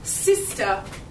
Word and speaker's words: Psister: Psister. – Sorry? – Psister. Psister. – Psister. – Yeah, with the silent P. As in cucumber.